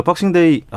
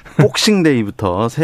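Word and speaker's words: Boxing Day: 박싱데이, 아, 박싱이 아니고 영국식이니까 복싱이라고 해야 되는군요.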